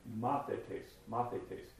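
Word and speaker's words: Mathetes (μαθητής): You want to use E for the A sounds, and so mathetes is what it looks like in English. mathetes is a disciple.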